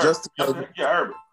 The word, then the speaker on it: Justin Herbert: Justin Herbert. Am I saying his name right? – Yeah, Herb. Justin Herbert.